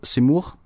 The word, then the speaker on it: Zemmour: Jamen, vi skal nemlig høre om øh, den øh, franske præsidentkandidat øh, Erik Zemmour. Zemmour, jeg håber, jeg udtaler det.